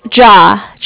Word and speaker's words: Ja: Ja. – Ja. – Kind of like J-A-H, if you want to, that's how it would sound like.